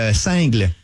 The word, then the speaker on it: single: Bon, ben, nous autres, là, on fait le festival de Saint-Tite, puis on a une pub pour nos trois nouveaux singles. – Non, c'est, ça se prononce comme le fromage, single.